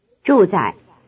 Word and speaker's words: 住在: Notice that the second syllable is in the neutral tone. – 住在, 住在, 住在.